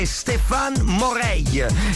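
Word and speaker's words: Stéphane Moraille: Il vero nome di Shauna Davis è Stéphane Morail, credo che si pronunci così, scritto Stéphane PH Moraille.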